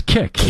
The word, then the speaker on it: Kik: It's Kik, isn't it? – Okay, yeah.